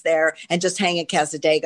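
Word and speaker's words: Cassadaga: Now you have workshops in, and I hope I say this right, but I've been there, Casa Casadega? – Casadega, yeah.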